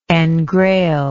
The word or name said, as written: engrailment